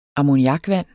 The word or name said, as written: ammoniakvand